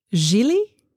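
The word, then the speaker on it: Gigli: They had the, was it Gilly? Rhymes with really.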